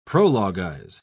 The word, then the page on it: prologuize: British English